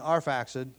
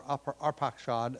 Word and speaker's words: Arphaxad: So there's an insertion of Canaan between Arphaxad, or Arpachshad, as it's spelled from the transliterating from the Hebrew rather than from the Greek, and Shelah.